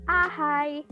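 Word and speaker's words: A-H!: Iya Joa, jadi A strip H tanda seru itu dibacanya ahai. Nah, kenapa sih kok bisa dibaca ahai sih?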